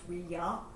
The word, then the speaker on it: Ouija: of Ouija comes from a combination of taking the French and German word for yes and smashing them together, which is why it should actually be pronounced Ouija, but it's not.